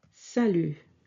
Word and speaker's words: salut: Hello in French is simply salut. Salut. Sa, S-A, sa, and lu, L-U-T. Yes, this T is silent. We don't pronounce it. So, salut.